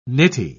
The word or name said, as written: nitty